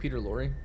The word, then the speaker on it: Peter Lorre: Is it Peter Lorre? – Yes.